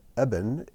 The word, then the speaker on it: eben: So, in this word, it's with a V sound, Av, but in the word eben, it's pronounced with a B sound.